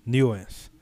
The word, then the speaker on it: Nuance: Nuance. É, falei certo? Ele sorriu, sim.